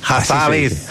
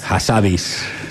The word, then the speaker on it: Hassabis: Demis Hassabis. ¿Se dice así? Hassabis. – Hassabis. Es un personaje. – Habibi, iba a decir como Habibi.